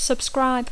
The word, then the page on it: subscribe: Catalan speaker; intermed